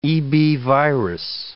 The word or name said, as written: EB virus